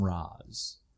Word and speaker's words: Ra's: But they talked about how they had to justify it because in the comic books they pronounce it Raish, but then all those Christopher Nolan movies kept saying Ra's, so now they had to find a way to meet in the middle.